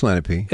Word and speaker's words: Lenape: So finally, Cigna kind of smacked me in the back of the head and said, this is how you say it. So it's Lenape.